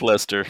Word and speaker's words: Leicester: Well, it's pronounced Lester.